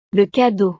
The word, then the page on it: le cadeau: frances